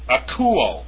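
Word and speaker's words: akouo (ἀκούω): So if we go back to the first form above, you'll see akouo, and you'll see an acute above the upsilon there. So it's pronounced akouo, with the accent on the U, all right?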